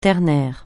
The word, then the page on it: ternaire: francais